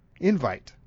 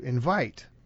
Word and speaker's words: invite: So when you send out an invitation, that is an invite. But the act of sending out an invitation is to invite, and that's the accent on the second syllable.